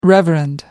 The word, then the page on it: reverend: American English